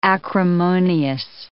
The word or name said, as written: acrimonious